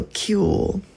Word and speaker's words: Qewl: I started this third out of four mini sets of my show with Cool by Socorro Violetov, and it's spelled Q-E-W-L, so Cool.